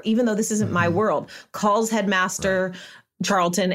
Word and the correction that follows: Charleston: and says, did I say that right? Headmaster Charleston. – I think you forgot the S. – Is it Charleston? – Sorry, guys.